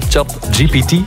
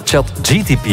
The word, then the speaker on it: ChatGPT: Ja, technologie-expert Pieter-Jan van Leemputten, nu heb ik het juist uitgesproken, hè? ChatGPT. – Uiteraard. – Ik zeg altijd ChatGTP.